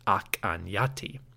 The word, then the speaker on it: Akhenaten: Based on studies of late Egyptian and the modern Coptic language, scholars suggest that the pharaoh's name, Akhenaten, was probably pronounced Akhenyati.